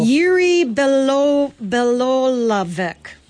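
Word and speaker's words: Jiří Bělohlávek: Jiří Belohlavek.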